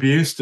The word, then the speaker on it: Buist: So So if you search for William Buist, and it's B-U-I-S-T on LinkedIn, you'll find me very easily.